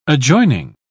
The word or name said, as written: adjoining